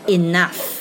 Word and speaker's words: enough: Enough，那有人烦了，说 It's enough，啊，够了，够了，够了，G H。就是发音 f，那么前面的 o u 发短音了，enough，enough。